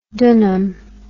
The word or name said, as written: dunum